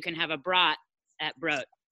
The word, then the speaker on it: Brot: And since we're going on pronunciations, like my last name, I'll also correct that brot is actually broat. – Oh, broat. – Yeah. Well, so broat means bread in German.